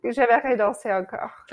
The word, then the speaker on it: J'aimerais danser encore: J'aimerais danser encore.